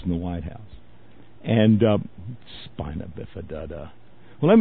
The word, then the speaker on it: spina bifida: And the man is so ignorant, he pronounced, he was trying to pronounce the name of a disease that could be cured. And it's clearly spelled, it's called spina bifida. Now, the way Bush pronounced it was spina bifida da.